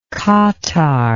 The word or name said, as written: katar